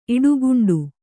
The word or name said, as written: ಇಡುಗುಂಡು